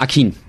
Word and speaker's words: Akin: Akin.